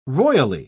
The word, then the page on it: roily: British English